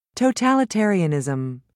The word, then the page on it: totalitarianism: British English